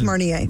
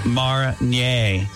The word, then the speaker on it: Marnier: M-A-R-N-I-E-R is how it's spelled. I think it's Marnier.